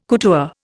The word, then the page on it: couture: Indian